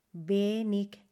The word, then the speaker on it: wenig: Wenig Deutsch. Atención a la pronunciación aquí, wenig, que es poco, se escribe con W, E, N, I, G, y la W en alemán se pronuncia V, V.